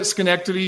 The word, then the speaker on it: Schenectady: You nailed it, Schenectady. You pronounced that properly.